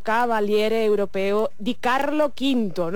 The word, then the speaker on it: Cavaliere Europeo di Carlo V: ha recibido recientemente en Italia el título Cavaliere Europeo di Carlo V, no sé si se dice así, lo tenemos con nosotros al otro lado del teléfono. Muy buenos días, Alberto. – Hola, muy buenos días, sí, sí, así se dice exactamente.